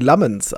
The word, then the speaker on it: Lammens: Wie spricht man den belgischen Torhüter aus? Ist es Lamont? Ist es Lammens? Ist es Lamens? – Laut dem, was ich immer so gehört habe von Kommentatoren, zumindest in der Premier League, Lammens.